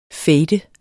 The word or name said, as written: fade